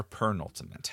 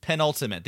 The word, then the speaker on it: penultimate: That concludes our discussion of the – is it penultimate or pernultimate? How do you say it? – Penultimate.